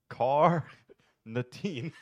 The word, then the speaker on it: carnitine: I was pronouncing carnitine. – You had it right. I'm really carnitine? – Surprised, but you had it right.